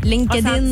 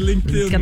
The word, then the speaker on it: LinkedIn: Ça se prononce de la même manière. – À peu près. – Tu rajoutes un D entre le N et le LinkedIn. OK, ouais, c'est LinkedIn. Ouais, c'est ça, c'est LinkedIn.